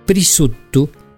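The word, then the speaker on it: prisuttu: El prisutto, fíjense ustedes esta pronunciación, prisutto, que en realidad es el jamón o prosciutto italiano.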